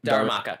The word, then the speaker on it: Darumaka: Darumaka. – Darumaka.